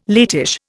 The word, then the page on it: latish: Indian